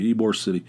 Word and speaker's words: Ybor City: But anyway, Ybor City.